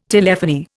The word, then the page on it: telephony: Indian